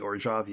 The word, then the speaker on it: Orzhov: You have to say Orzhov. – Is it Orzhov? – I think it's supposed to be Orzhov, yeah.